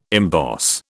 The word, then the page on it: emboss: British English